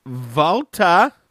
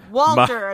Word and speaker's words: Walter: NXT UK, we open with Volta. – Thank you for saying it right, you and literally only Nigel. Nobody else, everybody's like, Walter. And I'm like, that does not sound nearly as intimidating.